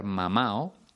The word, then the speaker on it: mamado: Por cierto, lo normal es que cuando escuches esa expresión, lo ha mamado, la persona que la pronuncie se coma la D. De esa forma escucharás lo ha mamado, no lo ha mamado. Vale. Y otra expresión más con este verbo, estar mamado o estar mamao, porque solemos comernos la D en estos casos, estar mamao significa estar borracho.